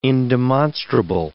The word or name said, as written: indemonstrable